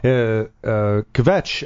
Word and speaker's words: Kvetch: So we're learning how to properly say Kvetch because the K and the V is like one thing.